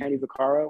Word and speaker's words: Vaccaro: Kenny v- Vaccaro, am I pronouncing that right? I couldn't remember who he was. Mm-hmm. Kenny Vaccaro. I think that's how you say it. Yeah.